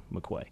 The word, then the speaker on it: McCoy: And so I was intrigued, and I was like, oh, do you really pronounce it McCoy? I bet I could fool him there, but I went and looked it up. It is actually McCoy.